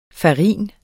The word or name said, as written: farin